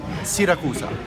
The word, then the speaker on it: Siracusa: The way we say in Italy, it's Siracusa. – Is that right? – It's right in Italian.